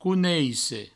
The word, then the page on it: coneisse: couneisse